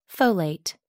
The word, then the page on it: folate: American English